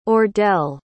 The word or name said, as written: ordell